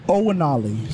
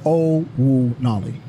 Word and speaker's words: Onwunali: Onwunali. So the N is silent in the name, so it's Onwunali.